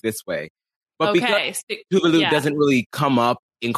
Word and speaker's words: Tuvalu: Oh, Tuvalu, also, by the way, I have been saying that wrong. Tovlo or whatever is how I was saying it.